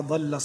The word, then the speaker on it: ضل: ما شاء الله تبارك الله يا محمد، قراءة طيبة مباركة، ولكن هناك بعض التنبيهات، في مثل قوله تعالى: ما ضل صاحبكم وما غوى، انطقها بالضاد وليس بالضاء.